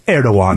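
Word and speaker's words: Erdoğan: The most important thing is to understand that even though it's spelled with a G, it's pronounced Erdogan.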